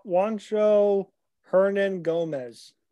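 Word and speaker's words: Juancho Hernangómez: How do you say his last name? – Go ahead. Juancho Hernan Gomez.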